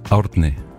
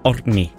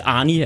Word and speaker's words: Orkney: Hvad var det rigtige? Må jeg lige få den? – Orkney. – Orkney. – Ja. – Altså, jeg kender en islænding, der hedder det samme som dig. – Okay. – Jeg vil sige, det hjælper efter 4 snaps at udtale det navn. – Ordentlig. – Ordentlig. Ja. Men vi kæmper lidt med den. Arnie er også okay. – Arnie er også fint.